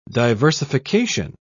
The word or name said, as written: diversification